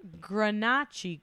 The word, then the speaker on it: Grenache: And it's the Grenache grape with the stress on the first vowel, which Gail told me, which I feel like really helped me to remember and also produce.